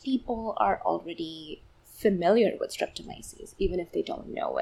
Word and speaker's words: Streptomyces: So you focus on the bacterial genus Streptomyces? Did I say that right? – Streptomyces. You were so close. – Myces. At the end.